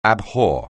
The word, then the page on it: abhor: anglais